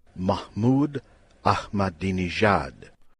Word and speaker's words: Mahmoud Ahmadinejad: On the website, you will see the name and hear Mahmoud Ahmadinejad.